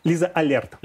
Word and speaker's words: Лиза Алерт: Не кажется ли вам, что использовать Лизу, а как правильно говорить, Лиза, Али, Лиза Алерт. Лиза Алерт.